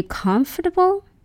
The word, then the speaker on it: comfortable: But when it comes to this word, comfortable, people tend to pronounce this word comfortable or comfortable.